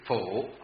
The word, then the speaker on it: phủ: Chữ phủ, à, đây là chữ bất nhé, nhưng mà nó có âm đọc là phủ.